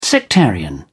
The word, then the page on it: sectarian: British English